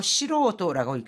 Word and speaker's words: 素人: 素人 같은 경우에는 좀 읽기가 지금 뭐 닝, 징과는 전혀 다르게 읽히잖아요. 잘 기억해 두시고 素人라고 읽습니다.